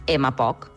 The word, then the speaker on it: MPOC: I segur que sentirem molt allò de la malaltia pulmonar obstructiva crònica, més coneguda per la sigla MPOC, que es pronuncia MPOC. I l'apostrofem, l'MPOC.